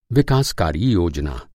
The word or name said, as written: विकासकारी